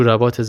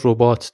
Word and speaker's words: رباط: بین راه رسیدن به روستای زعفرانی، تو رباط زعفرانی اتراق کردند. بعضی هم این واژه رو ربات تلفظ میکنن.